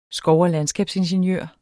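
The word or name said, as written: skov- og landskabsingeniør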